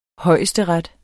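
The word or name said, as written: højesteret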